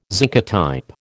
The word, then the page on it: zincotype: American English